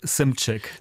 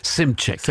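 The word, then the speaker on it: Szymczyk: Joe Walsh sagt Simcik. – Simcik, ja.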